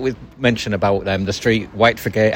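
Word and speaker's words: Whitefriargate: When you're from Hull, it's White Frigate, isn't it? – Frigate, yeah.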